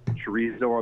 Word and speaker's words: chorizo: Someone taught me the proper way to say chorizo. I think I'm saying it wrong. – Chorizo. – Oh, wow. I think you're better than me.